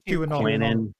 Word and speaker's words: QAnon: QAnon, whatever it was.